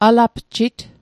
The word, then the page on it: alapjit: Mi'gmaq